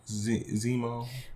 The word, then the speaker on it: Zemo: Zemo.